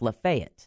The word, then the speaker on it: Lafayette: And interesting too, we have a county in Florida that's spelled just like Lafayette, but it's pronounced Lafayette.